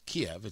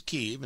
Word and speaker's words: Kyiv: They are absolutely not pulling out of Ukraine, while they may indeed be moving out of Kyiv, which is Kiev. It's just the Ukrainian way to say it is Kyiv. And once you learn it, you're like, okay, I will say it properly.